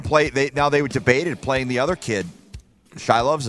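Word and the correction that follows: Silovs: is that how you say it? – Sealovs, whatever. – Sealovs, yeah.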